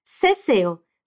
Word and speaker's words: seseo: A primeira é que essa pronúncia dos americanos de za, zo, zu, c e si com o som de S tem um nome, seseo. Se escreve assim, S-E-S-E-O. Fala, seseo. E a outra é que existe também o ceseo, que se escreve com C, C-E-C-E-O,